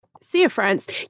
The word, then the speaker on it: Cianfrance: Cianfrance.